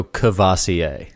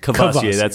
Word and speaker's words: Courvoisier: I lose that first R, and I go Courvoisier. – Courvoisier, that's what I know it as, too.